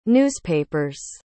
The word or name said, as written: newspapers